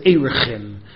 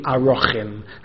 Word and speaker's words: Arachin: He once said in school, he once said that people refer to the Masecht as Erechin. He said that's the wrong pronunciation, it should be Arochin.